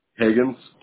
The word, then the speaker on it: Hagans: Higgins, H-A-G-A-N-S. – H-A-G-A-N-S, right? – Yep. – Higgins, okay. And Austin, you spell it A-U-S-T-I-N? – Yep.